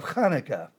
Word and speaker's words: Hanukkah: we begin the 8 days of Hanukkah. You say it just like that, it's kind of like you got a bone stuck in your throat.